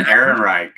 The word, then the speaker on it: Ehrenreich: Alden Eichenreich. Is that right? Alden Ehrenreich. – Ehrenreich. – It's technically Ehrenreich, but Ehrenreich works.